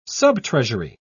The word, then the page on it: subtreasury: British English